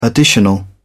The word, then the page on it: additional: British English